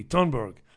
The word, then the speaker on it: Thunberg: They don't pronounce the TH. It's a hard T. Thunberg.